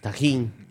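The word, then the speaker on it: Tajín: T-A-J-I-N. – Yeah, that's it. Tahine. – Tahina. – How did you spell that again? – T-A-J-I.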